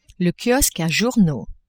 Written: kiosque a journaux

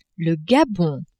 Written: Gabon

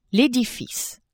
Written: edifice

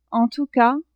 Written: en tout cas

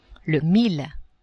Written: mille

